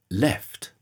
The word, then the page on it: left: British English